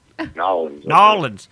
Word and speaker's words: New Orleans: How do you pronounce that? New Orleans, Narlands? What? – Narlands.